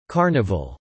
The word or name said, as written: carnival